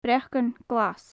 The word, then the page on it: breacan glas: gaelic